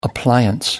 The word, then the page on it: appliance: American English